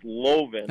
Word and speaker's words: Loven: My last name is pronounced Loven.